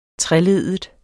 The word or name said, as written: treleddet